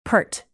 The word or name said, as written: pert